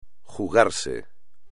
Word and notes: jugarse: Spanish

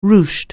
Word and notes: ruched: anglais

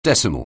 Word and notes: decimal: anglais

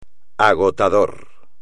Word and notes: agotador: espagnol

